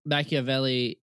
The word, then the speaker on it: Machiavelli: Machiavelli. – Yeah, Machiavelli.